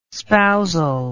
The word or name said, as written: spousal